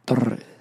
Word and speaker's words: Torres: I have that, be able to roll your R's sexy, like Torres.